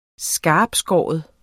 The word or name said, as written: skarptskåren